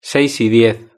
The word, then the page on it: seis y diez: European Spanish